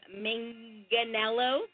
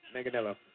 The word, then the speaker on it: Manganiello: So it looks like Sofia Vergara and Joe Manganiello. – Manganiello. – I said it right. I said it right. After I watched Magic Mike, I said I would never mispronounce his name again because he deserves that respect because he is awesome in that movie.